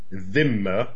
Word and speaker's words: dhimma: Anyway, it's based on the Arabic word dhimma. Dhimma, I know it's hard to pronounce. You can just say dhimmi.